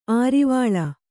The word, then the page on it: ಅರೆವಳೆ: arevaḷe